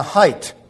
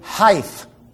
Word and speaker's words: height: 90% of people who speak English, even on television and radio, say height. There is no such word. There's not a TH on the end of that word. In fact, there is an HT.